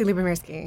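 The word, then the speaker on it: Lubomirski: Well, because Alex, the photographer, how do you pronounce his last name? Alex Lubarov, that's not right. – Alexei Lubomirsky. – Yeah, that was close.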